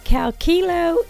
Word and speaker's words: Calcilo: So, Abbott Laboratories recalls Calkilo XD infant formula. Out of an abundance of caution, Abbott has voluntarily recalled a single lot of Calcilo XD powder cans, that's spelled C-A-L-C-I-L-O,